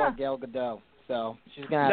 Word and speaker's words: Gadot: By the way, fellas, it's Gal Gadot, like seagull.